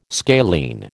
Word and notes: scalene: American English

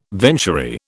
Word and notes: venturi: British English

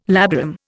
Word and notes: labarum: Indian

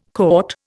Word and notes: caught: Indian

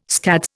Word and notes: scads: Indian

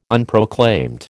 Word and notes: Unproclaimed: American English

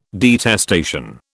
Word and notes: detestation: British English

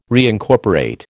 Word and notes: reincorporate: American English

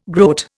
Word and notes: groat: Indian

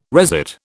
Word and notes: resit: British English

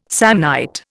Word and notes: Samnite: Indian

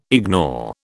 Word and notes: ignore: British English

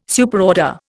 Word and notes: Superorder: Indian

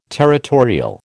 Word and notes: territorial: American English

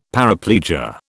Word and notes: paraplegia: British English

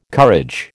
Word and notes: courage: American English

